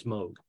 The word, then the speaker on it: Moog: And that is how you pronounce it, not Moog, it's Moog.